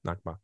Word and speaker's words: Nakba: Nakba.